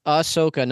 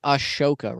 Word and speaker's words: Ahsoka: It's Ahsoka, not Ashoka, right? – Yeah.